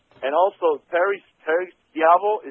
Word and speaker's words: Schiavo: Yes, it's pronounced Chiavo. – Oh, yes, correct.